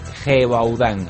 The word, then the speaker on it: Gévaudan: La bestia de Gévaudan, con G y con V. Es una región francesa, pero bueno, la pronunciación en este caso es prácticamente igual. Bueno, supongo que en Francia con sus matices, ¿no?